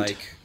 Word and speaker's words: Nike: But they thought it was Nike because it was spelled like bike. – So the fleet of foot. Nike.